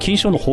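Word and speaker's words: 金匠: 金の匠と書いて金賞と読みます。詳しくは金賞の発起人の方がいらっしゃいますので、その方にもお話を聞いております。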